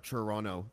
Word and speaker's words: Toronto: But when I go to a dispensary, say in Toronto, and I'm like, by the way, I've lived out of Toronto for 6 years, so now I pronounce the second T.